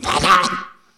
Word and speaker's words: Van Gogh: And I insisted that one of the Dutch people in the audience pronounce the word Van Gogh, because Dutch people don't even get near it. We say Van Gogh. We've always said Van Gogh. We're going to say Van Gogh. I'm not going to start pronouncing it in the Dutch way, because I don't know how. And I went up to a Dutch person and said, "Will you pronounce Van Gogh?" And she went, "Vah-vah!"